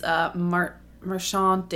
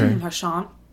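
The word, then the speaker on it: Marchánt: And then our last main actor is Marshawn Davis. I say it like that because he had an accent over his name. – Okay. – Marshawn Davis as Agent Taylor.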